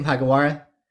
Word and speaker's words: Hagiwara: Kenta, how do you say your last name, Hagiwara? – Hagiwara, you got it perfectly.